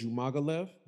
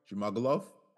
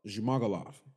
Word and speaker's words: Zhumagulov: So we got Charles Johnson versus Zlagas Zhumagulov. Did I say it right? – Zhumagulov. – Zhumagulov. Yeah. Sorry.